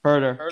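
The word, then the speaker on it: Huerter: Kevin, how do you say his last name? – Herter. Kevin Herter. – Yeah, Herter, he's 21.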